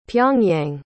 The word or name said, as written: pyongyang